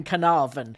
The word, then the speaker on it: Carnarvon: realised there's actually an R in Carnarvon.